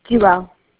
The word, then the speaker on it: Jewell: Jewel. – Please spell that for me. – J-E-W-E-L-L.